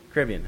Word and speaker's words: Caribbean: I think is it mostly in the Caribbean or Caribbean? How do you say it? Caribbean. Okay. I had it right the first time.